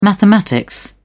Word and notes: mathematics: British English

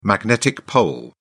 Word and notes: magnetic pole: British English

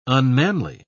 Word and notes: unmanly: British English

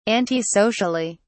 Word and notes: antisocially: British English

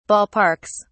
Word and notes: ballparks: British English

